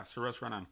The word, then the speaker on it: Saoirse Ronan: How do you pronounce it, Violet? Saoirse Ronan? – Ronan, that's what it is. Yeah, Saoirse Ronan.